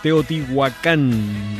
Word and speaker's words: Teotihuacán: Saluditos, saluditos hasta Teotihuacán.